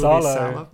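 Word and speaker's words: Salò: How do you pronounce it? Sallow? The movie Sallow. – Yeah. – The movie Sallow and the genderless asses in Sallow.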